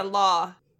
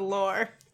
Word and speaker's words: law: So the law, not the lore.